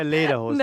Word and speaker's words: Lederhosen: I wore Leverhusen. – What? – Right, Raphael Honigstein. – You didn't wear Lederhosen.